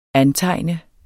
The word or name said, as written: antegne